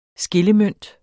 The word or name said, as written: skillemønt